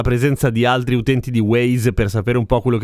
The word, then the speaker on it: Waze: Qualcuno di voi si ricorderà di Waze, che in realtà si legge Waze ed è un simpaticissimo gioco di parole perché Waze vuol dire anche strade.